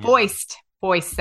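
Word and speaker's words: Fuest: Yeah, I'm probably saying that wrong. Foist. That sounds correct.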